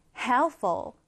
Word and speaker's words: helpful: 이럴 땐 강세를 다르게 helpful 앞에 강세를 강하게 두고 뒤엔 약하게 하는 거예요. 그러면 helpful. Helpful. 이런 발음이 되겠죠?